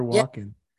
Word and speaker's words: Walken: Am I saying that right? Yeah, Christopher Walken.